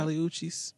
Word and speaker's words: Kali Uchis: Is it Kali Uchis? Uchis? – You said it right the first time. – Kali Uchis.